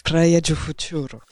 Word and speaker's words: Praia do Futuro: Vas-y, on la refait là. – Praia do Futuro.